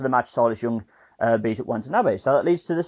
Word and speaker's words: Watanabe: Did I say that correctly? – Watanabe. Yeah, Watanabe. Close enough.